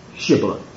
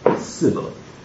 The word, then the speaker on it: Shibboleth: 42,000 Ephraimites were slain trying to cross the ford of the Jordan, getting back to their land because of their inability to say Shibboleth. They could only say Sibboleth.